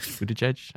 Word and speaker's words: Buttigieg: How about Steve Buttigieg? Am I saying that right? Buttigieg? – Yeah, but just, you called him Steve Buttigieg. – Steve. – You got the hard part right and the easy part wrong.